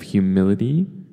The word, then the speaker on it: humility: Next, we have humility. Humility. And notice the emphasis on these two words. So the first is humble, and the second one is humility. Not humility or anything like that. Humility. Humility. And that is just the noun for humble.